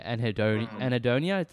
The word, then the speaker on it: Anhedonia: You have this one here, Anhedonia.